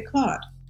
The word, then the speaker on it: Claude: In American English, we say Claude.